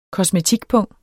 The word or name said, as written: kosmetikpung